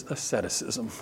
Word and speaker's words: asceticism: Well, look, if your physical body doesn't matter, then you quickly fall into what's called asceticism. Okay? Look at that word going, how do I pronounce that? Gabriel, that is asceticism.